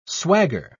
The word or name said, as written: swagger